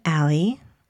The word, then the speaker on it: Ally: My mom gave me an unusual spelling, A-L-L-Y-S-O-N. So my nickname, Allie, is spelled A-L-L-Y. It's also can be pronounced ally.